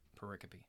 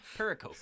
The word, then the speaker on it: pericope: One of my favorite parts of this verse, of this particular pericope, this story. – That's right. That's right. And that is not pronounced as I have pronounced it, perkiop and pericope. It is pericope, which is $1,000 word for a little tiny story.